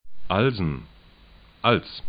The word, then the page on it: Alsen: 'alzn